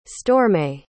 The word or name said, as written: storme